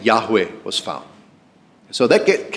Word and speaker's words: Yahweh: In Hebrew, I am was spelled YHWH. So it's probably pronounced Yahweh.